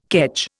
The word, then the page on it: kedge: Indian